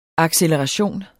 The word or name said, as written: acceleration